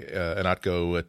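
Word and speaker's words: Ihnatko: As usual, the cost of admission to the Inatco Teen Funhouse is spelling my last name. You can spell it I-H-N as in Nancy, A-T as in Tom, K-O.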